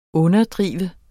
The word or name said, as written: underdrive